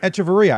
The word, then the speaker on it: Echeverria: I mean, we all talk about Echeverria. I think I said that right. – Echeverria. – I always forget that little extra syllable.